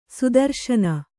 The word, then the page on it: ಸುದರುಶನ: sudaruśana